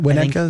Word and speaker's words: Winnetka: Winnetka? Winnetka is how it's said.